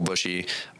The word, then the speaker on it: Buche: It's Cole Bushy. My last name is spelled B-U-C-H-E.